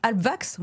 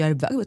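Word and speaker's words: Halbwachs: Vous êtes professeure de sciences politiques au département de sciences sociales de l'École normale supérieure, attachée au centre Maurice je ne sais jamais comment on le prononce. – Albvax. – Albvax, voilà, je dis Albvax, très bien, voilà.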